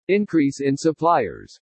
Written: INCREASE IN SUPPLIERS

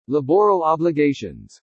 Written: LABORAL OBLIGATIONS

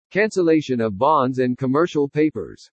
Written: CANCELLATION OF BONDS AND COMMERCIAL PAPERS

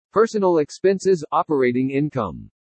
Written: PERSONAL EXPENSES-OPERATING INCOME

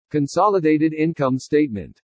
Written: CONSOLIDATED INCOME STATEMENT

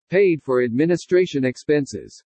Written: PAID FOR ADMINISTRATION EXPENSES

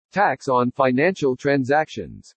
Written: TAX ON FINANCIAL TRANSACTIONS

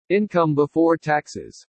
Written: INCOME BEFORE TAXES

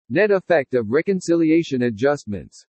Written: NET EFFECT OF RECONCILIATION ADJUSTMENTS

